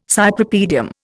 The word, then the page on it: Cypripedium: Indian